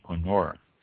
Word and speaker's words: Kerner: The Koenner, K-E-R-N-E-R. Is pronounced Koenner.